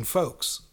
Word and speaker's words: folks: With that said, the L is silent in folks.